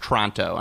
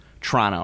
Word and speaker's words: Toronto: Sorry, I know it's pronounced Toronto. – Toronto. – I'm not supposed to say Toronto.